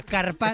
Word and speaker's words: carpaccio: Que te has perdido una letra. Carpaccio.